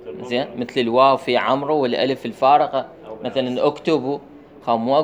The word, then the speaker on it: عمرو: زين، شون مثلا الواو في عمرو، هسه بعضهم يقول عمرو، هو خطأ، هذا الواو تكتب ولا تلفظ.